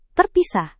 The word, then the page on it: terpisah: Indonesian